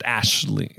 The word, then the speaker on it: Aisling: Aisling Franceschi, whatever. – Yeah, I believe it's pronounced Ashley. Like Aisling is like Ashley. – Sure. – It is a tough, it's an Irish name.